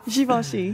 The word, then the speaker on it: Givenchy: How do you pronounce it? – Givenchy.